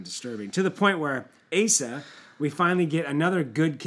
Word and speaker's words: Asa: It's pronounced Asa. And the only reason I know that, is because I know people named Asa okay. – after this king.